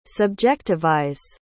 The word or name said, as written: subjectivize